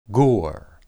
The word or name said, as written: gur